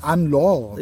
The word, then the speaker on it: Anne-Laure: Ich weiß nicht, es ist eine Französin, sie heißt bestimmt nicht Hannelore, auch wenn es so geschrieben ist. Ich würde mal sagen Anne-Laure. – Ja, genau. – Wird sie wahrscheinlich ausgesprochen. – Dann Carré. Also C-A-Y-R-E geschrieben.